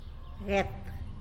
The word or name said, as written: guet